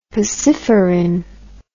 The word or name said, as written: pacifarin